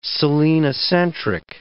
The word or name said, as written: selenocentric